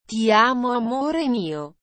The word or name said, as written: ti amo amore mio